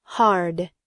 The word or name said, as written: hard